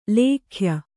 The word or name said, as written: ಲೇಖ್ಯ